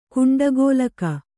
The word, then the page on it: ಕುಂಡಗೋಲಕ: Kannada